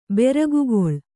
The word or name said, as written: ಬೆರಗುಗೊಳ್